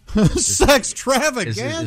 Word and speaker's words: Sextravaganza: Sextravaganza? – Sextravaganza? – Is that close to what we're trying to say? No, that's exactly it.